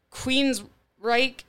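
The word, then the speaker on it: Queensrÿche: Queensryche, am I saying that right? – Yes.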